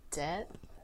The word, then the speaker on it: debt: Debt? – It's just debt. You don't pronounce the B. It's just DET. Debt.